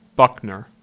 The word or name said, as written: Buckner